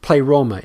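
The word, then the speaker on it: pleroma (πλήρωμα): It's pronounced pleroma in Greek.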